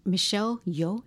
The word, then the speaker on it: Michelle Yeoh: I was so impressed with Michelle Yeoh, is that how you say her name? – Yes.